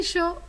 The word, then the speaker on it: Shaw: Tienen que poner Enrique Yo Oficial. Recuerden, por favor, que yo se escribe S-H-A-W.